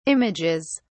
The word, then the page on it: images: British English